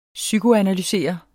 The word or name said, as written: psykoanalysere